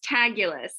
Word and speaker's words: Taguilas: No, it's Taguilas. It rhymes Taguilas. With fabulous. Yeah. – Okay, great. – But it looks like tequilas, the word tequila. And so People say it tequila, which it was totally fine.